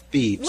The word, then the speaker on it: Phoebes: Is that how you say it? Phoebes? – Phoebes.